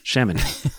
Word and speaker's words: Chamonix: And so then not too long ago, I got this new one and we like, how do we call this? Chamonix? – How you pronounce it? – Mm-hmm. Yes. Chamonix.